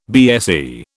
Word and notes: BSE: British English

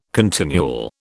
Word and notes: continual: British English

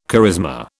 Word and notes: charisma: British English

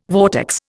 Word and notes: vortex: Indian

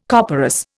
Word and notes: copperas: Indian

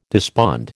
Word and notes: despond: American English

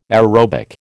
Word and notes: aerobic: American English

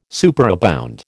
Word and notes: superabound: American English

